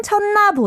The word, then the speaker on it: 쳤나: So there's this double 받침, 시옷 받침, which may make you feel a little confused and want to pronounce it, but you just pronounce it as 느 and just 쳤나.